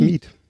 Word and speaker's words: Mieth: Nicole Mief. Miet? Wie wird das ausgesprochen? – Schreibt man es dann? – M-I-E-T-H. – Dann wahrscheinlich Miet. – Ja. Miet, okay.